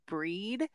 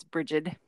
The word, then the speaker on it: Brigid: Breed. That's the Celtic pronunciation, but it's Brigid, if you don't pronounce it that way.